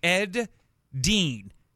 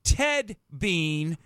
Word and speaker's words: Ed Dean: anyway, hey, by the way, many of you kill my name. No, uh, no, it's Ed Dean not Ted Bean, mm-hmm.